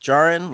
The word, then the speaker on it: Djarin: I'm pretty sure it was D-I-N. It was his first name, Din. D-I-N. And his last name was D-J-A-R-I-N. Like Jarin, like DJ.